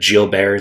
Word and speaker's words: Guillain-Barré: Okay, so Gilbert, but Guillain-Barré is how you actually pronounce it.